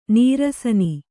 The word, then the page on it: ನಿರಸನೆ: Kannada